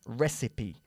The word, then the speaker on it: recipe: Así que recipe, pero se pronuncia recipe.